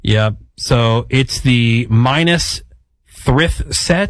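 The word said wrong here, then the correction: Minas Tirith: Did I say that right? – Okay. – I don't know. I don't know what Lord of the Rings. – Tell you. – MinasTirith, which is the capital of Gondor.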